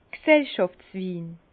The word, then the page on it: G'sellschàftswin: Bas-Rhin; Herrlisheim